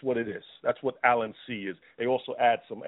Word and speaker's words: Allicin C: The product I'm talking about is called Allicin C, spelled A-L-L-I-C-I-N-C. Let me spell it again for you. Allicin C, A-L-L-I-C-I-N-C.